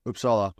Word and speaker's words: Uppsala: Is it Uppsala, Uppsala? – You kind of nailed it. Uppsala.